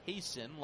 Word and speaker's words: Geison: 97 on the fastball there from Hasten, which, by the way, is spelled G-E-I-S-O-N, but pronounced Hasten like Jason.